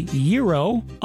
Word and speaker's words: gyro: How do you say the word spelled G-Y-R-O? Some people say gyro, others say gyro.